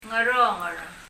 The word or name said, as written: Dngeronger